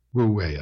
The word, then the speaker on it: Wu Wei: Wu Wei, I guess it's pronounced.